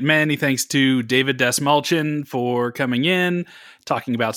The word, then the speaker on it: Dastmalchian: And I finally figured out how to say his name without saying it in my head first. – Yeah, you focused on the mal part, and you just Dastmalchian for a long time.